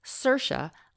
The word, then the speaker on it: Saoirse: Actress Saoirse Ronan helps people to remember the pronunciation of her unusual name by saying Saoirse like inertia.